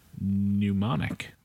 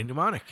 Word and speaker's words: Mnemonic: It's Johnny Mnemonic. – Johnny Mnemonic, yeah.